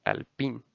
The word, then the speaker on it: Alpine: Y esto también lógicamente movió los campeonatos de constructores porque Raikkonen había sumado los primeros puntos para Alfa Romeo y Alpine, Alpine, perdón, porque ya vi cuál es la pronunciación correcta y es Alpine.